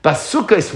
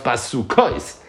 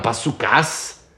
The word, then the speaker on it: basukos: Now the truth is, all three words are pronounced the same way. They're all pronounced basukos, which is Lashon Rabban. Not basukas, basukos.